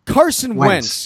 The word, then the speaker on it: Carson Wentz: Carson Wentz.